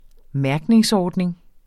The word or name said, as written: mærkningsordning